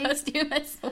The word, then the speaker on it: posthumously: Not unfortunately, following her passing, in November of 2013, Sally was posthumously awarded. Am I saying that correctly? – Posthumously awarded. – I already forgot how to say it. – Right. Try it again.